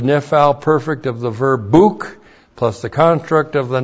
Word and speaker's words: buk: That's B long U K, buk.